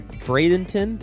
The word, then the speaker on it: Bradenton: And Kathy is based in Bradenton.